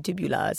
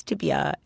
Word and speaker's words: tibia: Oh, well, I know so little about this that I actually realized I mispronounced tibia. I said tibula, so it's tibia and fibula.